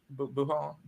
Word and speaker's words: Wuhan: Is that how you say it? Wuhan? – I believe that, yeah.